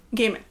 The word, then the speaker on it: Gaiman: I believe it's Gaiman or Gaiman? – Gaiman.